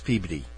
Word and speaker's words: Peabody: Spelt Peabody, pronounced Peabody.